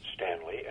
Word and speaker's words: Stanley: David Stanley, S-T-A-N-L-E-Y.